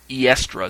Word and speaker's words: Eostre: was so named because of the goddess uh, Oestra, or Eestra, as I've heard it pronounced, is where it's, it's spelled E O S T R E. Oestra is what I've heard the best pronunciations.